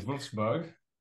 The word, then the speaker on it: Wolfsburg: In Wolfsburg.